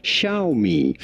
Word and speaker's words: Xiaomi: This word is pronounced in Chinese. Xiaomi.